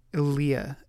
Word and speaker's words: Ilia: Just before this, she was Ilia? – Ilia. – Ilia. Emphasis on the Lee? Ilia? – Ilia.